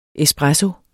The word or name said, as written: espresso